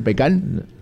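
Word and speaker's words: pecán: ¿Sabes lo que es el pecan? – No sé. – Las nueces. La nuez, el verdadero nombre de la nuez es pecan, con acento en la A.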